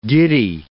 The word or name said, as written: giddy